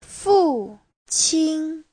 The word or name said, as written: fu4qin1